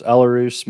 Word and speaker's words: Elehuris: Uh, is it Elehuris Montero? – Uh, that was pretty close. Elarus Montero.